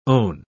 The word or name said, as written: own